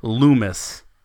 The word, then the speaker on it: Loomis: It unfortunately is not pronounced like hummus. It's Chase Loomis.